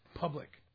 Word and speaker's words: Publix: Yeah, it's spelled P-U-B-L-I-X, public.